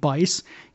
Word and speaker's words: Buice: You may remember Dwayne Bice. You probably mispronounced his name over and over again if you pulled his card. B-U-I-C-E.